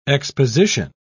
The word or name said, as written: exposition